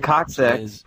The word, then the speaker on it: coccyx: The coccyx, pronounced coccyx, Common referred to as a tailbone.